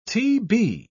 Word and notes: t.b.: British English

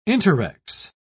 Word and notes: interrex: British English

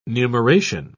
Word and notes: numeration: British English

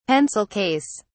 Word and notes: pencil case: British English

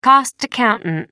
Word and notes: cost accountant: American English

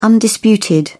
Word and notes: undisputed: British English

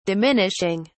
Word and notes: diminishing: British English

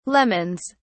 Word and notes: lemons: British English

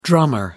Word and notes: drummer: American English

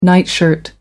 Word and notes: nightshirt: American English

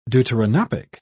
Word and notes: deuteranopic: British English

